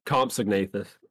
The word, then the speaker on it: compsognathus: Is it pronounced comp synopsis? – It's compsognathus.